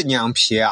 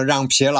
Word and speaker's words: 酿皮: 第二个叫莫高酿皮，酿皮这个食品啊，我在西宁吃过，西宁的酿皮啊，呃非常有名。在敦煌呢，他把这个酿字念成让。其实这是一个错误的读法，但是敦煌人这么念就成了让皮了。